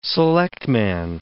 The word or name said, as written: selectman